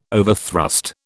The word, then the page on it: overthrust: British English